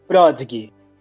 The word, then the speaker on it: Prodigy: Não sei se pronuncia assim, pronunciei certo? – Prodigy.